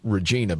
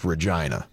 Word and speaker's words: Regina: Well, if you saw it, you might say it's Regina, but it's actually pronounced Regina. – Mm-hmm. – R-E-G-I-N-A.